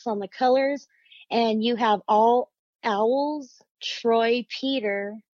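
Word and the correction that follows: Peteri: as letters. I hope I said that right. Pateri, maybe? – Pateri, yeah. Sorry. Pateri.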